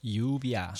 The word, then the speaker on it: lluvia: It's spelled, it sounds like a Y, but it's spelled L-L-U-V-I-A. And that double L letter is actually a letter in the Spanish alphabet, and it's an ella. So we were going to pronounce it in Espanol. It'd be ella, u-v-i-a, lluvia.